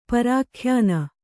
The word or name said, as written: ಪರಾಖ್ಯಾನ